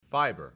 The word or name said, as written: fibre